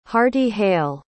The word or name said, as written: heartyhale